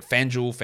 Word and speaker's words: FanDuel: And I realize that, I know in America you don't pronounce it that way. You call it FanDuel. Duel. That sounds so stupid to me. I can't get my mouth to do it. If I say D-U-E, it's due.